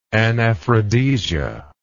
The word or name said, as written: anaphrodisia